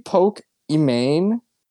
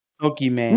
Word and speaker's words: Pokimane: Did you know that's not even how her name is supposed to be said? It's supposed to be Pokimane? Because her name's Imane? – Yeah, that's how they say it. Pokimane. – No, everyone just says Poke. Yeah, no, it's supposed to be Pokimane. There's supposed to be a pause.